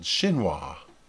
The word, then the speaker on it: chinois: It's spelled C-H-I-N-O-I-S. It's called chinois.